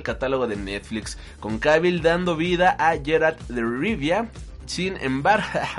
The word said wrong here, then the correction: Geralt: con Cavill dando vida a Geralt de Rivia. ahí sí está bien pronunciado.